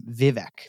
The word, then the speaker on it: Vivek: But his name is Vivek. That's how you say his name, Vivek. – That's so interesting. On the All In, they still say Vivek every day. They talk about him a lot and they keep so I was like, are we saying it wrong?